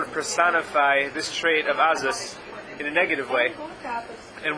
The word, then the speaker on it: Azus: Can you phonetically spell that word, Azus? – Azus. A-Z-U-S.